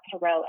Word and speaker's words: Karelle: Yeah, so on Instagram, my Instagram handle is just my name, so at Carell Edwards, so I'll spell that K-A-R-E-L-L-E, Edwards with an S at the end.